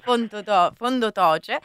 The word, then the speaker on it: Fondo Toce: Allora, io ovviamente ricordo che la Casa della Resistenza di Verbania si trova in via Turati 9 a Verbania, Fondo Toce, giusto? – Esatto. – Ho detto bene? – Esatto, ecco. Località Fondo Toce. – Fondo Toce, e tutte le informazioni eventualmente le trovate sulle vostre pagine, ne deduco.